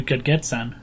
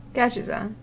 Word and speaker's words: Gadgetzan: I'm looking at the screenshot for the new Gedgetzan. – The new what? – Gedgetzan. – Gedgetzan. – In Tanaris. – Gadgetzan.